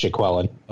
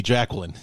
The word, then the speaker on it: Jaquelin: That, I believe it's pronounced Jaquelin. – Okay, because yeah, that's that's the I'm only other choice would be Jacqueline. I don't think that's right.